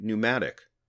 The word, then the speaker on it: pneumatic: even words like pneumatic, where the P is silent.